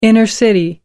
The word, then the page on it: human being: British English